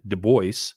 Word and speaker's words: Dubois: Reavers, go out to Sheridan, Wyoming, go up through the Bighorns, hang a left, go down through Thermopolis, through Riverton, up to Dubois, or as they pronounce it, Dubois.